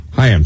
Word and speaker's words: Chaim: It's pronounced Chaim, though, I think. – Chaim, sorry.